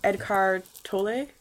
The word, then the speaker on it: Eckhart Tolle: And I remember listening to even yesterday that Eckhart Tolle, yeah.